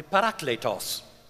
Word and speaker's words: parakletos: The word in Greek is simply paraclete. – 那希腊文这个字叫做 paraclete. – Or if I pronounce it properly, parakletos.